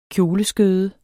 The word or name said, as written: kjoleskød